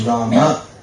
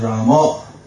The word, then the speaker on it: Rama: The general Sanskrit pronunciation is Rama. – Традиционное санскритское произношение будет Рама. – Bengali pronunciation is Ramo.